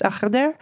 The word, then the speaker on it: Al-Khidr: Al-Khidr is spelled in English Al-Khidr. And if you Google it, you'll see a lot of different spellings, and that's totally fine. But it's just whatever looks like it might be pronounced Akhder is probably referring to him.